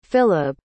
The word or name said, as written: filib